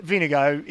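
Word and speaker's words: Vingegaard: Vinigo is actually the correct pronunciation, by the way. Vinegar in Danish.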